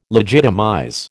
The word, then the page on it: legitimize: American English